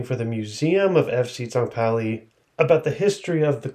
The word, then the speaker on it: St. Pauli: FC St. Pauli or St. Pauli in the English pronunciation.